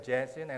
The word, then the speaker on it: Jasin: So my name is Jacob Jasin, and my last name is spelled Jasin and not Jason.